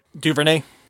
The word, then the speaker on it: DuVernay: So her suggestion as well, perhaps having Lin-Manuel Miranda do something, or Ava DuVernay. Am I pronouncing that right? – DuVernay.